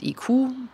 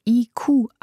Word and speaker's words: ICU: Ich habe die operative Leitung vom Projekt IQ, was ausgesprochen internes Crowdsourcing in Unternehmen bedeutet. – Spricht ICU also IQ aus weil für sie das Thema Schwarmintelligenz eine zentrale Rolle spielt.